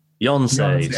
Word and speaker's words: Yonsei: It's the Y, Yonsan? Yonsei. Sorry, I always get that wrong.